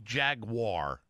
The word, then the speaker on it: Jaguar: I wish to hell I would know the correct pronunciation of Jaguar. – Jaguar. – What is it again? – Jaguar. – Just see, that's Jaguar. Guar. Jaguar. – Jaguar. – See? – Like guar. – Yeah, like guar.